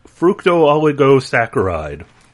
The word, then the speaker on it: fructooligosaccharide: Fructooligosaccharide. – Oh, there you go. That's the way to pronounce it.